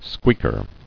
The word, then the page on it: Squeaker: squeak·er